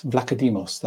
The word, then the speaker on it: Vlachodimos: Now, we're saying this, you know, Odysseus Vlachodimos, that's how you say his name.